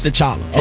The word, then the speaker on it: T'Challa: Pronounced T'Challa.